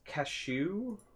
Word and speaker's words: Keshu: Keshnu? – It's Keshu. – Like the nut? – Like K-E-S-H-U. – K-E-S-H-U.